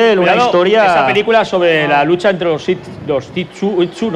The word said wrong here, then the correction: Tutsi: ¿no? ¿Cómo se dice? – Sí, los Hutu y los Tutsi. – Los Tutsi.